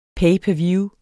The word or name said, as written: per